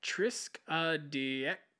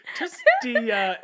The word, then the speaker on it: triskaidekaphobia: The origin that I found was the word for the fear of the number 13 is known as triskaidekaphobia. Triskaidekaphobia. Triskaidekaphobia. Tris, like 3. Triskaidekaphobia. – Is that Trace or something? – Well, it's spelled T-R-I-S-K-A-I-D-E-K.